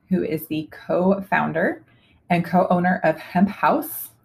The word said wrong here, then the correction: Haus: or Hemp Haus, rather, H-A-U-S. – Use the German pronunciation.